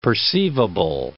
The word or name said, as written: perceivable